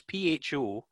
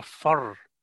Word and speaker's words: pho: Well, it's, I mean, I know that when it comes to Vietnamese food, I'm always a great fan of the, and I can never pronounce it properly. It's pho, and I always want to say pho. – Yeah. – But then I think it's more of a fur. People call it fur,